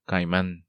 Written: Caiman

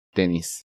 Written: Tenis

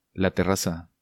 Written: La Terraza

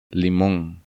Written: Limon

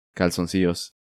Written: Calzoncillos

